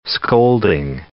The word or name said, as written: scolding